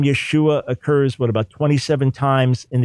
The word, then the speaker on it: Yeshua: We feel good about this pronunciation, Yeshua, and find no evidence for Yeshua and the name.